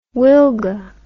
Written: wilga

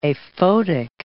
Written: aphotic

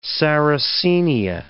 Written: sarracenia

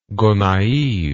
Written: gonaives

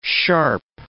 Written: sharp

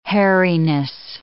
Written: hairiness

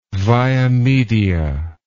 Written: via media

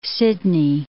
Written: Sydney

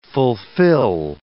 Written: fulfill

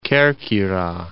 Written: Kerkyra

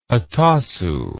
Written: hatasu

